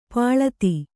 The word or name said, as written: ಪಳಿತ